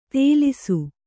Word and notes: ತೇಲಿಸು: kannada

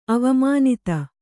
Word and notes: ಅವಮಾನಿತ: avamānita; kannada

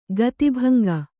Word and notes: ಗತಿಭಂಗ: gati bhaŋga; kannada

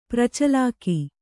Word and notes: ಪ್ರಚಳಾಕ: pracaḷāka

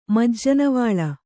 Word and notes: ಮಜ್ಜನವಳ್ಳ: Kannada

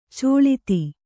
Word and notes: ಚಳತೆ: caḷate

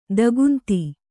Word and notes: ದಿಗಂತ: kannada

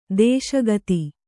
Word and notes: ದೇಶಿಗಿತಿ: dēśIgiti

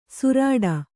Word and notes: ಸುರಾಡ: surāḍa

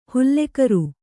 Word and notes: ಹುಲ್ಲೆಕರು: hulle karu

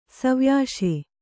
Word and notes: ಸವ್ಯಾಶೆ: savyāśe

